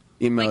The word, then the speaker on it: Emoto: Is Dr. Yamamoto, I'm sorry, I'm butchering his name. – Emoto. Very close. – Emoto. – Like emotion, like emote. – I didn't mean that. – Yeah. E-M-O-T-O. – M-E-D-J. – M-E-D-J.